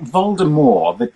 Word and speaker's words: Voldemort: I mean, you have to pronounce it Voldemort. The T is silent, as though he were French or something, which is news to me.